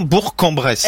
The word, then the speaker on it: Bourg-en-Bresse: On prononce bien Bourg-en-Bresse.